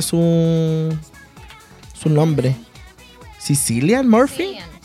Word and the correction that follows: Cillian Murphy: Cillian Murphy.